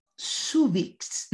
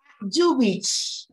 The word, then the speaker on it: Zuvic: Pedro se escribe, ahí está escrito, ustedes lo pueden ver, Subix, pero me corrigió, me dijo que es Subix. – Yubish, porque no ah, tiene los acentos. Yubish porque es croata.